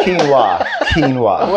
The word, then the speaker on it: quinoa: So those are Genoa seed Triscuits, are they? – Quinoa. – Whatever seed. – It's called, I don't know. – It's pronounced quinoa. – Quinoa.